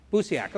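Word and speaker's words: Busiek: Can someone pronounce his name for me? Busiak. Okay, thank you.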